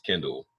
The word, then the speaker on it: Kendall: You can find me on Twitter at CoachGreen27, coach with a K, because my name is Kendall.